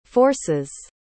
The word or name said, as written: force's